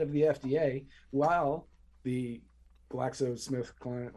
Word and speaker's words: GlaxoSmithKline: GlaxoSmithKline or Smith GlaxoCline or GlaxoSmithKline or whatever.